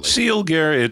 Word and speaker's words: Sealgair: Am I pronouncing that properly? – Sealgair, it's Gaelic for, you know, the leader of the hunt.